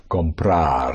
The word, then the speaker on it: comprar: The key to the whole present tense is to stress always the syllable before last of any verb. Therefore, if you want to say to buy would be comprar.